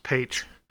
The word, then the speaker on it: Paich: And it's pronounced Paige.